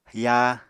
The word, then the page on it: yaa: Apatani